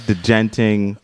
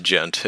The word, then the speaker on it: djenting: Spelled D-J-E-N-T-I-N-G, yes. Am I right? Okay, yes. – Yeah. – What is degenting? Just yeah. So it's just pronounced gent. It's not like degent. – Oh, excuse me. The D is silent.